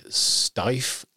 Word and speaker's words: Steiff: And he was manufactured, and I'm going to probably mangle the pronunciation here, Steiff? – Correct. – Steiff Company.